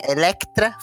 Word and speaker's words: Electra: Você tem a Elétrica, você tem. Electra, falei errado.